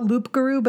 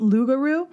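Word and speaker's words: loup-garou: but it's it's spelled out loop garou, but it's pronounced lougarou.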